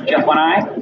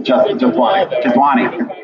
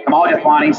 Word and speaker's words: Jethwani: Am I going to say this right? Jethwanai? – Jethwani. – Kamal Jethwani, Senior Director for Connected Health Innovation Partners Healthcare.